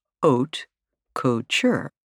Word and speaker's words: haute couture: For women, it's known as haute couture, pronounced haute couture, a French term that translates to high sewing.